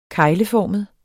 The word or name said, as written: kegleformet